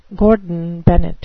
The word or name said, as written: Gordon Bennett